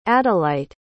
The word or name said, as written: adelyte